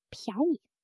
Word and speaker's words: 便宜: 便宜, 便宜 means cheap. Notice the second character loses its tone in natural speech. It becomes a neutral tone.